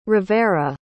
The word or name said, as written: rivera